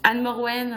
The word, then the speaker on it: Anne-Morwenn: Anne Morwen, euh, Anne ou Morwen, les trois fonctionnent.